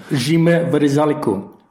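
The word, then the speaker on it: Šime Vrsaljko: Jimé Vrsaljko.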